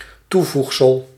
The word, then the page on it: supplément: [sy.ple.mɑ̃]